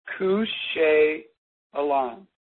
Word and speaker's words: Kusheyalan: they would often exchange the watchword Kusheyalan. It's pronounced like it's K-O-O-S-H-E-Y-E-H-L-A-N, but it's spelled a little differently, but it means, how is your heart?